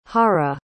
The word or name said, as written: hara